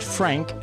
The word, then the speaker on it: FRNK: So we had Heartbeat here on Relative Ability, and the next song is FRNK, pronounced Frank, Outrun City.